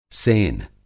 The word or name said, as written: सेन